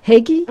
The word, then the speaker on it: hygge: The hygge? Say it again? Hygge? – Hygge.